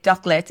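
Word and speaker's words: Dốc Lết: I'm trying to remember how it's spelled. – Yeah, D-O-C. – What? – L-E-T. So I understand it, Doklet in English, in the sound, yeah, Yoplet. It's a difficult word.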